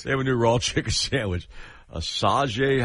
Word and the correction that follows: Asiago: how do you pronounce that? – Asiago. – That's it. Yeah.